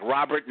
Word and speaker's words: Robert: Type in my name, Pascal Robert. It's Pascal. Last name is spelled like Robert, no S.